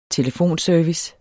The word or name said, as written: telefonservice